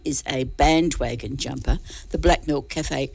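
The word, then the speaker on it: BLK MLK: It's Black Milk. I'll say Black Milk throughout the article because it's spelled BLK space MLK.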